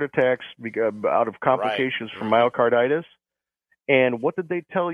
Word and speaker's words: myocarditis: Conducted an analysis of regarding the elevated risk of heart attacks due to myocarditis. Is that how you pronounce that? – Myocarditis, yep.